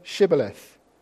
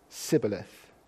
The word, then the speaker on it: Shibboleth: When he said, "No," denying his true identity, they said to him, "Then say Shibboleth." And he said, "Sibboleth," for he could not pronounce it.